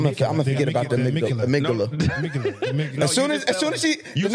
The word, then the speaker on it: amygdala: Amygdala. Amygdala. – Make sure you use the I didn't know there was a D in there. Amygdala.